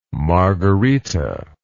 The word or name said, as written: margherita